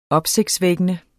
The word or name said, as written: opsigtsvækkende